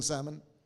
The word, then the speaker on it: Salmon: My wife and I enjoy eating salmon. But how is it spelled? S-A-L-M-O-N. But the L is silent, right? But there's a male name in Israel, S-A-L-M-O-N, where the L is not silent, it's pronounced.